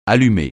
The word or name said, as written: allumer